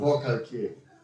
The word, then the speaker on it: Forcalquier: I can't pronounce Forcalquier. – Forcalquier. – Yeah.